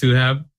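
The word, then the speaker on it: Suhaib: Mine is pronounced Suhab. The I always throws people off, so it's not a big deal at all.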